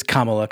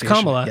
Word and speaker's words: Kamala: It is Kamala Khan. It is the same as the vice president. – It is pronounced Kamala.